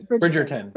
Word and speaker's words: Bridgerton: Am I saying that right? Bridgerton? – Yes. Bridgerton.